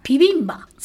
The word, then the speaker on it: ピビンバ: ビじゃなくてピなんですか。ピビンバ。